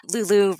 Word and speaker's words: Lulu: Lulu rhymes with poo-poo.